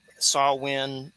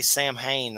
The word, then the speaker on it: Samhain: That's just like some creepy Samhain. I always say Samhain. That's not how you say it. It's Samhain. Samhain.